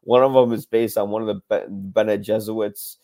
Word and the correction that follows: Bene Gesserit: Am I saying that right? Bene Gesserit or Jesuit? Bene Gesserit.